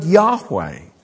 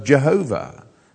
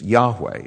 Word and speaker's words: Yahweh: That capital L-O-R-D is the Hebrew word Yahweh that's been mispronounced for generations as Jehovah. It's Yahweh is the correct pronunciation.